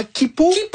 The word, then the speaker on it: Quipu: Et si vous pensiez que c'était déjà vertigineux d'imaginer l'Agnakéa, attendez qu'on vous parle de comment Kipu. – Ça s'écrit Q-U-I-P-U.